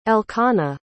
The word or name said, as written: elkanah